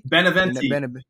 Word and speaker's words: Beneventi: Beneventi. – Beneventi. Beneventi. Is that Italian? – Beneventi. Gabagoo. – Okay, so it is.